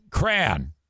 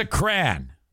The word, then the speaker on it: crayon: For mispronouncing Cran. It is a crayon. What do you call it? A crayon? Yeah, why why would I do that? There's several ways you can pronounce it. I just think that mine is correct. Cran. Get out the bust out the crayons. You might catch me from time to time saying crayon, but to me you sound like an asshole when you say that.